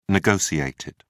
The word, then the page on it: negotiated: British English